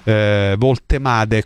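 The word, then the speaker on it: Woltemade: Voltemade, così si pronuncia, ho sentito un amico tedesco,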